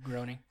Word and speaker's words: Groening: But the biggest of them all is Matt Groening, I believe is how you pronounce his last name. – Groening.